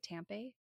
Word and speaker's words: Tampe: Am I saying that right? Tampe? – Yeah, it's Tampe. – Like I-E.